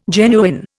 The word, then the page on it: genuine: Indian